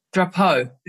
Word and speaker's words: Drapeau: How do you say his last name? – Drapeau. – Drapeau, yeah. French.